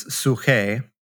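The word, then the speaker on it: psuche (ψυχή): And when you get into the Greek, as you were suggesting, you know, the word here for life is psuche. That's how it's pronounced, but it's spelled like psyche.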